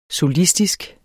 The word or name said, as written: solistisk